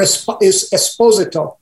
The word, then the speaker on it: Esposito: As a fan myself of all of us who make the show, fans of Giancarlo Esposito and the wonderful, or Esposito, I believe is the correct pronunciation.